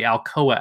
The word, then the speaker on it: Alcoa: Yeah, this is actually an animated adaptation of The Stingiest Man in Town, which was an episode of the NBC anthology series, The Alco Hour, or The Alcoa Hour. It's spelled A-L-C-O-A, which was one of these like mid-50s kind of variety show sort of things, right?